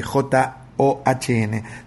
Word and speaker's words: Sean: Por supuesto, porque es el saludo de John Lennon, pero no del John de J. O-H-N, sino del hijo de Sean. Se escribe Sean y se pronuncia John.